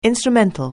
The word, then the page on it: instrumental: anglais